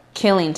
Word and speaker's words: Killeen: Not killing like K-I. – Not. – I go Kai. K-I-L-L-I-N-G. Not killing Texas.